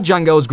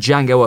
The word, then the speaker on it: Django: Django appearance in the flick at one stage. Ultimately, said heroic arrival was scrapped, but Django's presence was still very much felt. And never forget, the D is silent.